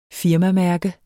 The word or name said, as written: firmamærke